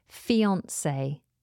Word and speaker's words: fiancée: Next on the list is a noun, and it is fiancé. Fiancé. Now, fiancé is spelled F-I-A-N-C-E-E. But the first E in fiancé has that little accent above it.